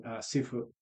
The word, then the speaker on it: Sifu: And for those of you who don't know, I believe the Cantonese pronunciation, Sifu, is the character used to spell that, is the combination of the word teacher and father, right?